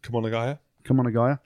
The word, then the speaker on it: Kumagaya: South Africa dominating Japan 41-7 in Kumagaya. Happy with that pronunciation? – Kumagaya.